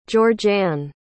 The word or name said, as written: georgeann